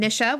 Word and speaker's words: Nisha: And then our last legendary moment comes from Nisha with a short I.